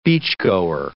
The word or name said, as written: beachgoer